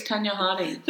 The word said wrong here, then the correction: Tonya Harding: Like the ice skating. Oh, Tonya Harding. – Did you not know who I was talking about from that mispronunciation?